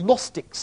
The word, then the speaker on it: Gnostics: Most of these alternative stories of the resurrection were written by members of cults called the Gnostics, spelt with a G, in the 2nd century AD and later.